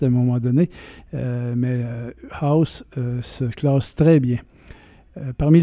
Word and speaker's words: Huis: Ça s'écrit H-U-I-S, mais ça se prononce House.